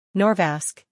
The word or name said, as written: Norvasc